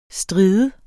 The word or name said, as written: stride